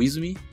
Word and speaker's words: Izumi: Eu sempre pronuncio errado o nome dele, tá, mas é o Izumi.